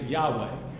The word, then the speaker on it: Yahweh: You and I pronounce it Yahweh. We had the vowels, but they never did.